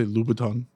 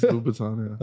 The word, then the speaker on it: Louboutin: He's trying to say Louboutin. – Oh, is that how you say it? – It's Louboutin, yeah.